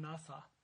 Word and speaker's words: nacha: The word here for tested is N-A-C-H-A, is pronounced Nasa, and it means to prove or to try.